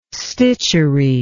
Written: stitchery

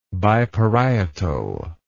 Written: biparietal

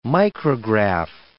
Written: micrograph

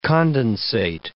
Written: condensate

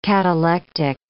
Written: catalectic

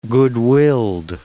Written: goodwilled

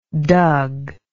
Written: doug

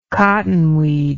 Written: cottonweed

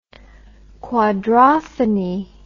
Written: quadrophony

